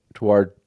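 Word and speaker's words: Tawadros: Well, and I mean, again, and this is, you know, so this week, Pope, help me with the pronunciation, Tuardros? – Tuardros. Tuardros. The head of the Coptic Orthodox Church, Pope Tuardros.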